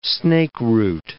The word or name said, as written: snakeroot